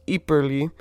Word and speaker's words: Ypres: Sorry, I mispronounced it. It's Ypres, but yeah, that's where the name of the town came from, as it's named Ypres, based off of the name of the river.